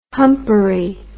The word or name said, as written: humpery